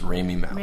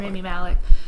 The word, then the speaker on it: Rami Malek: I think, how do you say his name? Is it Remy? – I think it's Rami Malek. – Rami Malek.